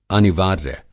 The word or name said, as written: अनिवार्य